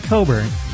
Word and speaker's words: Cockburn: See, Democratic candidate Leslie, it's spelled Cockburn, I'm going to pronounce it Coburn, tweeted on Sunday that in addition to campaigning with a white supremacist, Republican candidate Denver Riggleman was a devotee of Bigfoot erotica.